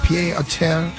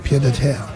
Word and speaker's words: pied-à-terre: Pied-à-terre tax? French name. Pied-à-terre.